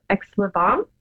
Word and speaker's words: Aix-les-Bains: I hope I say the name correctly, but it's Aix-les-Bains.